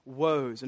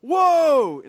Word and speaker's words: woe: I think they're there, but he directs this language from 13 on straight to the Pharisees, and he starts pronouncing woes. And it's not like, whoa, like W-H-O-A. It's woe, like W-O-E.